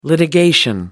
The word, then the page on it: litigation: American English